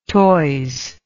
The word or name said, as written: toise